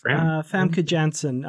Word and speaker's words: Famke Janssen: Framka Janke played it. Is that the way you say her name? – Famke Janssen, the perfect mate.